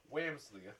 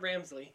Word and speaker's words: Wamsley: Calgary acquires Rob Ramage and Rick Wamsley. Yes, I said it right. Wamsley. – It's not Ramsley. Wamsley.